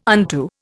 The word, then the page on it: unto: Indian